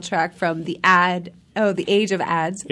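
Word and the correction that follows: Adz: So the Age of Odds, it's A-D-Z. – Not O-D-D-S. – No, not O-D-D-Z.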